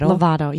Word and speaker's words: Lovato: But say her name again, Demi Lovato? – Lovato, yeah.